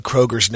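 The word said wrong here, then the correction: Kroeger: name wrong all this time. – Krieger? – Kruger. – Oh, Kruger. All right. Like Mr. Kruger.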